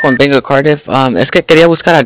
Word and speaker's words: Cardiff: Se llama Cardiff, se escribe como C-A-R-D-I-F-F.